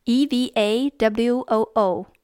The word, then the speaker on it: Eva Woo: Probably easiest to reach me on Twitter at my name, which is spelled at E V A W O O.